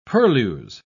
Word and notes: purlieus: British English

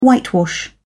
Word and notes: whitewash: British English

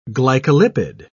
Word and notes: glycolipid: British English

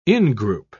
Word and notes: ingroup: British English

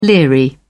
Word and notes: leery: British English

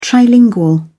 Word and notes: trilingual: British English

